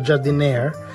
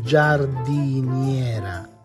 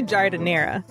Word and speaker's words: giardiniera: There for sure is not jardiniere, but it's giardiniera. – Personally, I say giardiniera.